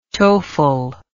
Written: toefl

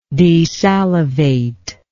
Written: desalivate

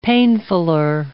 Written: painfuller

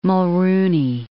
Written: Mulroney

